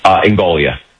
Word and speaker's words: Ingoglia: Am I pronouncing the last name correctly, sir? – Ingoglia. – Oh, the second G is silent.